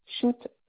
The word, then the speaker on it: Chout: Shoot, C-H-O-U-T.